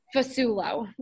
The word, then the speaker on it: Fasulo: So it's at Alexandra Fasulo, which is spelled F A S ULO.